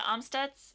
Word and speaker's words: Amstutz: I'm Sandra Amstutz.